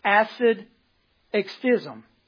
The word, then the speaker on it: asceticism: A-S-C-E-T-I-C-I-S-M. It's pronounced acid extism. It's a tough one.